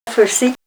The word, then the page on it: farsit: Ninilchik Russian